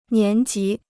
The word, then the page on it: 年级: nián jí